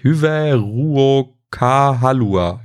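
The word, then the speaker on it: Hyvää ruokahalua: und zwar Hyvä Ruokahalua. War das jetzt richtig? Hyvä Ruokahalua. Ja, doch, so heißt es.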